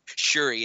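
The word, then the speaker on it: Syuri: Yeah, so is her name still Suri? – Shuri is how you say her name. but yes, it is. – Okay. – Siri. She's facing Siri? – Hey, it's S-Y-U-R-I.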